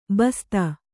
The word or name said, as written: ಬಸ್ತ